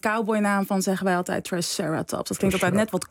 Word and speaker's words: triceratops: Het is triceratops of triceratops, toch? – Ja. Het mag alle twee. – De ene is Latijn meer? – Ja, de Latijnse, correct Latijnse uitspraak is klemton op de ene laatste klinker, dus triceratops.